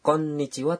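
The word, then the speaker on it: konnichiwa (こんにちは): Pengucapan Ang dalam konnichiwa terbilang sulit. – Ya, ucapkan konnichiwa dengan mengetuk irama 3 ketukan. Sekarang mari kita ucapkan bersama sambil mengetukkan tangan kita.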